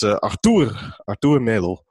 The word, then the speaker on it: Arthur Melo: according to the Wikipedia, as we call him, it's Arthur Melo. The O's in Portuguese are always kind of odd O's. So Arthur Melo, as I will...